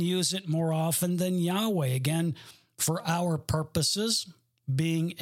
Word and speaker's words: Yahweh: The alternate claim is that the corresponding letters of the Tetragrammaton are YHWH, and with similarly and but different vowels, it is pronounced Yahweh. Similarly, they had to add vowels. They added a couple of extra vowels, those that claim it's YHWH. We pronounce that Yahweh.